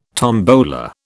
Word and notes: tombola: British English

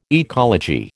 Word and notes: ecology: American English

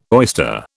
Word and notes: oyster: British English

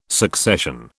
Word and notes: succession: British English

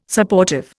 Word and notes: Supportive: Indian

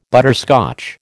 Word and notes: butterscotch: American English

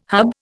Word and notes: hub: Indian

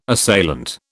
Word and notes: assailant: British English

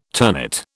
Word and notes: ternate: British English

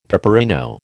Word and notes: peperino: American English

